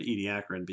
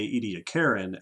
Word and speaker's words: Ediacaran: and wondered how to pronounce it, we are pronouncing it Ediacaran because I used to say Ediacaran and then an Australian man scolded me.